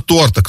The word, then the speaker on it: торта: Именно торта, кто-то сказал бы, наверное, с другим ударением, но я скажу, как по грамоте прописано.